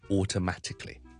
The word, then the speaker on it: automatically: 放在上面那一点我们叫做后面这个 syllable 是 primary stress，而 all 就是有个 secondary stress，所以你当是两个音我都是比较强的，所以 automatically 这个就叫做两个重音的一个字。